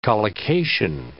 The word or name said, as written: collocation